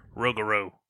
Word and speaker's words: Rougarou: You have to say it more Cajun, like Rougarou.